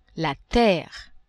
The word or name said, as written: terre